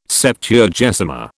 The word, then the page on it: Septuagesima: British English